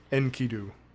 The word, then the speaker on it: Enkidu: So they called him Enkidu, this wild man of the countryside. – Say, how did you say it? – Enkidu. – Enkidu? Okay. – Yep. Like Yankee Doodle, Enkidu.